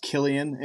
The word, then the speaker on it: Cillian: He's like if, and I believe it's pronounced Killian if you're from that area of Dublin Killian.